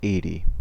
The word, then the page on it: eighty: American English